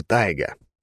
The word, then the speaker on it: Тайга: Или нет, лучше тайга. Ударение на первую «а».